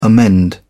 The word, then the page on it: amend: British English